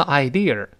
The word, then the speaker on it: idea: 另外后面这个 idea，idea 又是个想法呀、念头啊，但是在这里呢，它不能读成 idea。好多同学就容易呢，一看美音了就卷舌吧。李老师说过，没有字母 r 的时候，舌头是不能往回勾的啊，一定后面是就是啊。